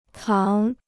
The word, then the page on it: 扛: gāng